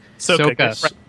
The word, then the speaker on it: Sokka: Who is Sokka? – Soka. – Oh, I'm so sorry.